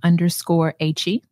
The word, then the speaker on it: Achie: I am _Achie. My first name is spelled A-C-H-I-E.